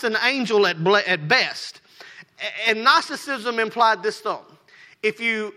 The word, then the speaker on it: Gnosticism: It sounds like it starts with an M. I don't understand it. But it's called Gnosticism.